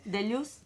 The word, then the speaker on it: Deleuze: Deleuze es un...